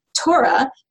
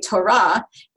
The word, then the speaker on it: Torah: So we say Torah, the penultimate stress, and some Jews do say Torah intentionally to try to sound closer to that Israeli norm.